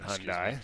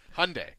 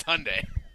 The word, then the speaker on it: Hyundai: It is not Hyundai, just so you know. It's become my mission in life at Sportsnet 650 to make sure that every one of the hosts knows how to properly pronounce Hyundai. – Hyundai. – Hyundai. – I butchered it myself a few times. – So when they signed you up, they were like, okay, listen, there's one thing you got to know here. It's Hyundai.